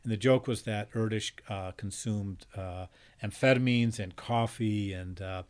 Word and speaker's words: Erdős: Oh yes, it looks like it ought to be pronounced Erdős, and I for a long time said mistakenly Erdős, because it's leaving out the so-called diacritic marks, you know, little dots and dashes and check marks and stuff over the letters. The name is spelled E-R-D-O-S. – Yes. – Paul, but it apparently it's pronounced something more like Erdős.